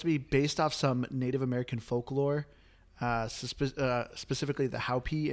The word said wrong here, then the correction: Hopi: Indians. Hopi. Sorry, I pronounced it wrong.